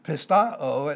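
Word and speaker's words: pistoo: There is the verb pistao, it's a P-I-S-T-O-O, a short O and a long O, and this only occurs once in the New Testament, and it's in 2 Timothy 3:14, means to be convinced.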